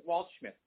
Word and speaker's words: Waldschmidt: But Dan Waldschmidt, did I get that right? – You got it.